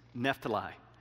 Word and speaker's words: Naphtali: But it's not pronounced Naphtali. It's Naphtali. And then I joked with him. I said, you got to remember, I'm from small town Alabama. You can take the boy out of small town Alabama. You can't take the small town out of the boy. So there's ways that we pronounce things with the wrong syllables, so you'll have to bear with me. But Naphtali is how that's said.